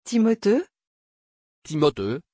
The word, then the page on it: timotheus: French